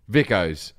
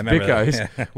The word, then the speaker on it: Vikos: they were between Pepsi and Coke, but I have a cola brand that I drink when I'm in Greece, Vicos. It's spelled V-I-K-O-S. Vicos.